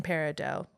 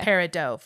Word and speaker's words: peridot: So most jewelers and gemstone collectors don't pronounce the T. We say peridot, following the French. But don't worry, it's also correct to say peridot, which is an acceptable American way to say it.